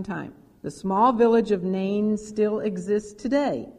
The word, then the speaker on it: Nain: They walked 25 miles down into the Jezreel Valley to the city of Nain, which is on the southern border of Galilee.